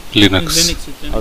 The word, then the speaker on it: Linux: Linux, I don't know how to pronounce it. Linux or Linux? – Linux.